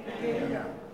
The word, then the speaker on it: Kenya: Is that how you pronounce it? Kenya or Kenya? How do you pronounce it? – Kenya.